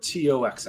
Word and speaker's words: T.O.X.X: Uh, uh, are we pronouncing this Tox or T O X X? Tox, correct. Tox. Okay, cool.